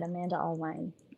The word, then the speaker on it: Amanda Alwine: You did, Amanda Alwine.